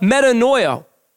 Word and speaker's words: metanoia: Well, repentance is the Greek word, and I'm not going to pronounce this perfectly, but metanoia.